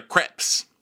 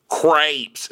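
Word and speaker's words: crêpes: Oh, I guess in America they're pronounced crepes instead of crepes. So it's crazy. That's crazy how people just ignore an accent grave.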